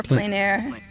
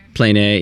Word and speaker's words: plein air: I was reading up about, when I looked at your Instagram, I was looking at some of the plein air painting. Is that how you pronounce it? – Yeah, or plein air. – Plein air, yeah. And